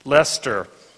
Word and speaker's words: Leicester: She finished her doctoral work at the University of, how do you pronounce that? – Leicester in England.